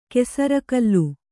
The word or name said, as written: ಕೆಸರಕಲ್ಲು